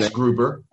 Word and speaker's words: Eisgruber: Okay, so President Eisberger, I believe that's how you say his name. Eisgruber. – I beg your pardon, President Eisgruber. Mm-hmm. I got my G and my B confused.